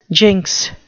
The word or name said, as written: jinx